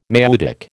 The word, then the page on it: maieutic: American English